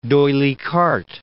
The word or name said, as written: Carte, D'Oyly